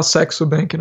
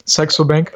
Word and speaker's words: Saxo Bank: O nome do banco é o Saxo Bank, é isso que você falou? – É para não falar Sexo Bank, né? Porque pronuncia-se Saxo Bank.